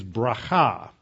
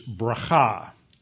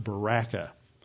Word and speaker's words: bracha: And so that's the correct way to pronounce the word for blessing, not Baraka.